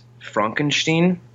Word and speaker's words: Frankenstein: It's pronounced Frankenstein, actually.